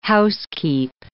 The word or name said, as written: housekeep